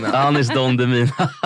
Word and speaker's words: Anis Don Demina: Och Danis Don Demina, var det rätt uttalat? Don Demina. Ja, Don Demina, Demina, herregud, alltså för lyssnarnas skull så är Danis Don Demina. – Anis Don Demina. Det är så dåligt. Danis. Nej, Anis Don Demina.